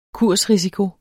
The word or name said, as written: kursrisiko